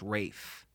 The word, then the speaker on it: Ralph: Ralph Fiennes, right? – Ray. – Ray Fiennes? – Yeah, it's spelled like Ralph. – Yeah, yeah. Ray Fiennes. – Actually, I think it's pronounced Rafe.